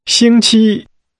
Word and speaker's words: 星期: This is pronounced with 2 high-level tones. – 请说。 – Weeks. – 星期。